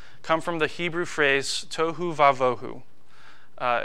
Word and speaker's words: tohu vavohu: So, tohu vavohu. It's spelled T O H U space V A dash V O H U.